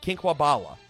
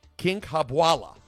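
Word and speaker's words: Kinkhabwala: Andrew Catalan, joined by James Lofton and Aditi Kinkwabala. I think I butchered that. Kinkwabala. There we go.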